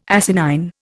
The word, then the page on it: asinine: Indian